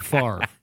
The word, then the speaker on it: Favre: And that's Minnesota Vikings legend Brett Favre.